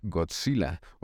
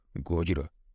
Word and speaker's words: Gojira: El día de hoy presentamos la primera parte de Godzilla o Gojira, como es... debidamente pronunciado y como lo voy a hacer durante todo el programa.